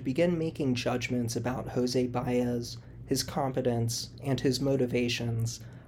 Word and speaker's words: Baez: In the original miniseries, I mispronounced his name as Baez until a friendly listener eventually pointed out my mistake and corrected me. For the record, it is pronounced Baez.